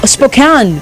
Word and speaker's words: Spokane: How do you say it again? – Washington State. – Spokane. – Spokane.